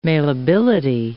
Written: mailability

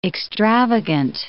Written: extravagant

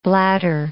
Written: bladder

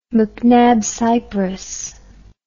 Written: macnab cypress

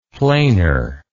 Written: planer

